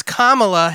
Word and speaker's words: Kamala: For not saying her name right. It's Kamala Harris. – Kamala? – But Kamala.